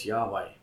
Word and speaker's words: Yahweh: and it's generally understood today to be correctly pronounced as Yahweh.